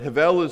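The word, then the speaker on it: hevel (הֶבֶל): The Hebrew word there for vanity is hevel. I need a lot more spit in the back of my throat to say it correctly. But hevel is the word there, and it can mean vanity, it can also mean futile, and it can mean fleeting.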